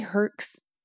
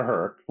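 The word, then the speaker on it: Huerik: Those are Herks. Well, no, the sculptures are not Herks. – Okay. Are you saying Herks like there's a vowel there? – Yeah, how is it spelled?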